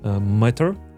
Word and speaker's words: Matter: Э, есть, конечно, вопросики, э, потому что мои девайсы, они, они работают по протоколу Matter, вот так это произносится, и они изначально нативно подключены именно к Apple HomeKit.